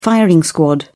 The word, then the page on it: firing squad: British English